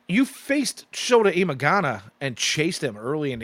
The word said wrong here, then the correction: Imanaga: Or Imanaga. I'm sorry, I said that wrong. I caught myself. I was like, wait a minute, that didn't sound right.